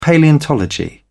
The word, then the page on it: palaeontology: British English